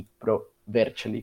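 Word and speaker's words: Pro Vercelli: Boloña y Pro Bercheli, creo que es la pronunciación correcta, con 7.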